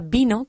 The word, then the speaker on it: vino: La palabra vino, que hemos visto como dos por uno, se pronuncia exactamente igual. Vino se pronuncia exactamente igual que beso. Vino, beso. Vino, beso. Nunca decimos vino. Vino. Nunca. Siempre vino, vino, como una B, siempre.